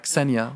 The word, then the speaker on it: Xenia: But they do I pronounce it right? Xenia. Yeah, Xenia.